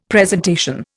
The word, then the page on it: presentation: Indian